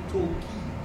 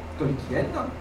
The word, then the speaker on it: Tolkien: Donc, je vais vous parler donc de Tolkien, ou pour ceux qui ont envie justement d'apprendre des langues, en fait, monsieur Tolkien ne s'appelait pas Tolkien, il s'appelait Tolkien. Si vous le prononcez à l'anglaise, c'est monsieur Tolkien. Mais comme on est en France et que la plupart des gens le prononcent à la française, on continuera à dire Tolkien. Quant à mon nom à moi, c'est un nom d'origine lituanienne, écrit à la polonaise.